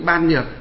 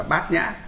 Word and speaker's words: Bát Nhã: Nhưng mà nhà Phật chúng ta thì đọc trại âm đi, chữ ban nhược đọc là bát nhã.